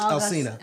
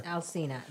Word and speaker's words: Alsina: So bottom line is, we're talking about masculinity and femininity, and we're about to talk about Will and Jada in this whole Alsina. Is that how you say his name? – August. – August Alsina. – Alsina, yeah.